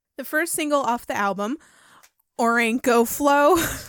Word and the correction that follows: Orinoco: Orinoco Flow, there. There it's you go. Orinoco. – Oh, okay. I stand corrected. – Yeah, you said that wrong. It's Orinoco.